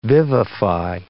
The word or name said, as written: vivify